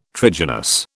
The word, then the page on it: trigynous: British English